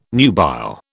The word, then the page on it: nubile: British English